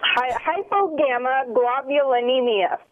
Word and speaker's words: hypogammaglobulinemia: It's an immunodeficiency that I have called hypogammaglobulinemia, which I've had to practice pronouncing a lot. – What's the last word? Globulinemia? – Hypogammaglobulinemia.